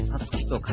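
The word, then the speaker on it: 五月: さつきと書いて 5 月と言う、はい、違うっちゅうね。 – 合ってるよ、合ってるよ。 – さつきと書いて5 月やで、ね、何が言いたいの。 – いや、5月と書いてさつきと読むと言いたかった。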